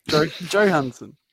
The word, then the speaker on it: Johansson: Johansson. – Johansson. – Johansson. – Oh, I thought it was a silent J. – Soft J, like yogging.